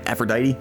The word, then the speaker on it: Aphrodite: Aphrodite? – Yeah, the goddess of love.